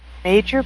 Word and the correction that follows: Majel: Barrett Roddenberry? – No, Majel. Majel Barrett Roddenberry, Gene Roddenberry's wife.